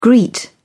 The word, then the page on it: greet: British English